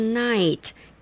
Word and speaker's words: knight: 武士叫 knight， K-N-I-G-H-T，注意到那个 K 没有发音哈。